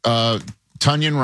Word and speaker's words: Tonyan: Jalen Warren, You know, they got Robert Tonyan, rhymes with Funyan, to go along with the wagon, Darnell Washington, and of course, Freiermuth.